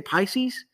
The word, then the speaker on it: Pisces: And I'm the Aquarius, the Pisces, how do you say Pisces? – Pisces, yeah.